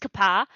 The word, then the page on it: ǩaπa: Phyrexian